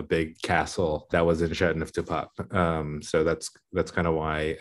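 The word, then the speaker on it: Châteauneuf-du-Pape: So Châteauneuf du Pape is the pronunciation.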